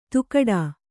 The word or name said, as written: ತೂಗಾಟ